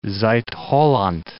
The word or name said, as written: Zuid-Holland